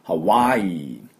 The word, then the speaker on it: Hawaii: ね、実はスペルがね、 H-A-W-A-I-I なんです。で、これ、ハワイってちょっと伸ばすんですね。正しくは。ハワイ、イーです。で、この、ハワのところは、喉の奥で、っていうふうに、喉で鳴らします。ハワイ、ハワイって感じでね。で、あとおの真ん中ぐらい、喉、ハワイ、ハワイですね。これが、正しいハワイの音で、ハワイではなくて、ハワイ、ハワイ、このハワのところにアクセントがあります。これだけ要注意。